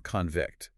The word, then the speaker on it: convict: The classic example is the noun convict, with stress on the first syllable, versus the verb convect, with stress on the second.